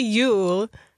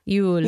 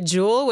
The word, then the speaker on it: Yule: How do you say it? – I can say Yule. – Yule. – Yule. But I've heard Yule. A lot of people say jewel, which makes me happy because they think it's like a jewel, right? – Yeah, J-U-L, like the Swedish cookbook.